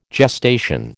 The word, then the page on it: gestation: American English